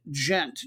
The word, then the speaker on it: Gent: And your match, it is, I believe you pronounce the club name with a soft G, Ghent, G-E-N-T. Even though the town they're from is G-H-E-N-T, and you would say that as Ghent with a harder G.